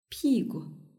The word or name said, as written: pigu